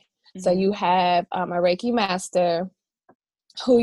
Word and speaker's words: Reiki: So you also are, and I always fuck this up when I say it, Reiki. Did I say it right? – That's right.